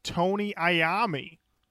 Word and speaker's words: Iommi: How is Tony's last name said? – I am no Black Sabbath historian. – Just tell me. No. Don't you dare. Tell me. Tony Iommi? – That's how I have always heard it pronounced.